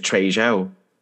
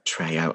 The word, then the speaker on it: Trejo: Is it Trejo? – Trejo. – Oh, crap. It's because I pronounced the J.